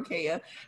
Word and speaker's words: Kaya: I literally go by both. That's what Amanda said yesterday. Yeah, really? Amanda said yesterday. She's like, I feel so bad. I've been pronouncing your name wrong. I'm like, no, it's not, because I either say Kaya or Kaya.